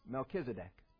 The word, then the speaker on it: Melchizedek: It's just pronounced Melchizedek. That's the way we do it.